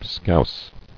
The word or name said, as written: Scouse